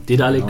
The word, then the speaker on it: Daedalic: Ich wollte gerade sagen, in diesem Zuge sei Daedalic, äh, Dedalic genau.